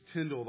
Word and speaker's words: Tyndale: But this is the life of William Tyndale. His name, his last name rhymes with spindle.